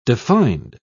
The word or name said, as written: defined